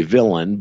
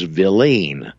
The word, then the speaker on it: villein: And we say villain, but the correct pronunciation is villein, V-I-L-L-E-I-N, villein.